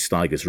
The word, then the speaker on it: Stigers: Now, on the Steve Wright Show at the time, they always, whenever they referenced Curtis Stigers, they always said, Curtis Stigers rhymes with tigers.